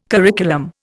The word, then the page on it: curriculum: Indian